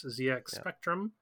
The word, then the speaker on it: ZX Spectrum: Oh, it's British. Right. That makes it's a British machine. You got to say it right. – I was going to say, I think you've mentioned both that system and that game on this podcast before. – Maybe. Every time that Brett says ZX Spectrum, I correct him.